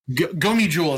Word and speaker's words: Gomiju: The Jomoju? My Korean is not good. – The Gomiju. – Yeah. – Gomiju is how it's pronounced.